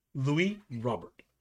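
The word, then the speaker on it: Luis Robert: I know that for a fact because I listened to the pronunciation guide. Louis Robert. Take that one to the bank.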